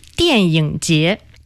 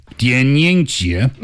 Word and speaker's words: 电影节: For example, how to say the film festival. – Tell me. – 电影节. – 电影节.